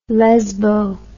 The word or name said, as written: lezbo